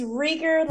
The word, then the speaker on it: Rieger: It's rigor like eager.